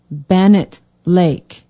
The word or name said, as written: benet lake